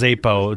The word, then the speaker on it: Xapo: Zappo? – It's pronounced Zapo. It's X-A-P-O. – X-A-P-O.